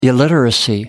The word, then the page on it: illiteracy: American English